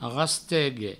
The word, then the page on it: rastegue: provençal